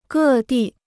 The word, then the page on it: 各地: gè dì